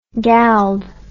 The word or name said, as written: gald